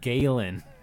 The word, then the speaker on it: Galen: He went to come out and talk to Galeon Erso. – Galen. – Galen. Thank you.